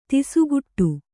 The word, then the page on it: ತಿಸುಗುಟ್ಟು: tisuguṭṭu